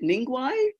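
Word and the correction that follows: Ningaui: So it's a Ningawi.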